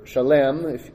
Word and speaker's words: Salem: He's king of a city called Salem, I guess, or Shalem, if you want to pronounce it more like it would have been then, maybe. We'd say Salem.